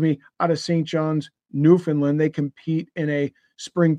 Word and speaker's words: Newfoundland: And it's pronounced Newfoundland.